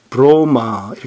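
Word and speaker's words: 브로마 (βρῶμα): 예수께서 이르실 때 나의 양식은 양식이란 말은 헬라어로 브로마 이렇게 발음이 되는데 사람이 먹는 음식 푸드를 지칭해요.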